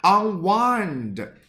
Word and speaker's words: unwind: Unwind，注意这个单词发音可不是 unwind，它跟 wind 风没有任何的关系，不要把它理解为风干。